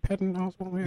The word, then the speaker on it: Patton Oswalt: Yeah, I mean, there was, what was it, Patton Oswalt just put out a, I think I just said his name wrong, holy cow. – No, I think it was right, Patton Oswalt.